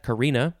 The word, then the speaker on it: Carina: How do you say that? Carina. Not visible north of 37 degrees latitude.